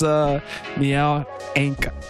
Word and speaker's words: Nial Ainca: He's like, yo, no, this is Nial Ainca. I said that right? – Yes.